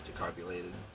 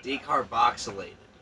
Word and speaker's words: decarboxylated: You can actually, can't you actually eat the cannabis like immediately after it's decarbulated? – Mm-hmm. – I don't even know how the fuck you pronounce it. – Decarboxylated.